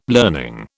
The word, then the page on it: learning: British English